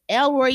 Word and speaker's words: El Roi: That is E L R O I.